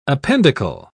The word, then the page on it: appendicle: British English